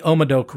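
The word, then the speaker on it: Haut-Médoc: And it's from the Omadoc region. Again, hot Madoc is what it looks like, but it's pronounced Omadoc.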